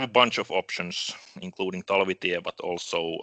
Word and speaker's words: Talvitie: I think we were looking at New Jersey Devils prospect, potentially, Arni Talvitie. Am I saying that right? – No, Talvitie.